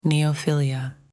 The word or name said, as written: neophilia